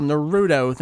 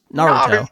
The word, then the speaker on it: Naruto: That seems to be another thing that Americans have difficulty with, is rather than a flat pronunciation, there's always the middle syllable that they put that weird emphasis on. And that's where the horrible Naruto thing comes from, rather than just Naruto.